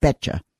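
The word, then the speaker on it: betjak: B for boy, E-T-J-A-K, pronounced betcha.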